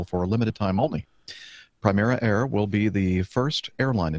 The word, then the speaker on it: Primera Air: Primera Air, am I pronouncing that correctly? – Yes.